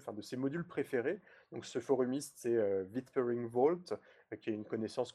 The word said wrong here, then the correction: Whispering: Non, whis Whispering